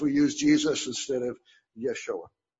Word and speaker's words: Yeshua: I mean, even in our use of Jesus, that's not the correct pronunciation of Jesus either. Do you remember what it really is? Yeshua.